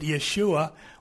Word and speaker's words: Yeshua: Actually, they pronounce it Yeshua.